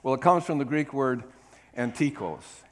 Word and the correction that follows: Antidikos: Now let me say that again because I didn't say it right. Antidikos. Antidikos.